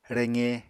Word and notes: reqe: Apatani

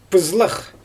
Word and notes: pyzlax: Xyzyl

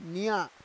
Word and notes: nya: Roon

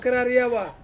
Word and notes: keraria wa: Ansus

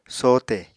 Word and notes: sohte: Apatani

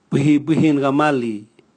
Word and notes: bwihibwihi gamali: Raga

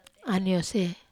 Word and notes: aniose: Wamesa

